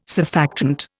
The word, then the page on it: surfactant: Indian